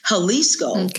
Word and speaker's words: Jalisco: Jalisco starts with a J, you know, pronounced like with an H, Mexican, right?